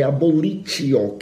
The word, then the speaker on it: abolitio: Olha, o artigo 2 ele começa lá no caput do artigo 2, né, ele começa trazendo a figura da abolicio criminis Abolicio se escreve com T, é abolitio que se escreve, né?